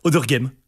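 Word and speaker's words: Auderghem: Presque. Odergame. Le petit i ne sert pas.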